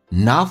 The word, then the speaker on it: not: Work, work for a For 跟 a 有一个连音叫 for a, for a cause, 英式发音叫 cause, alright, 美式发音嘴巴打开一点点 cause, work for a cause, not for applause, not 的 t 不发音叫略音，后面是以辅音开头，嘴巴慢慢的打开 not for applause, 英式发音叫 applause, 美式发音打开口腔一点点叫 applause, one more time, applause.